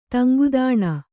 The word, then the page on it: ತಂಗುದಾಣ: taŋgudāṇa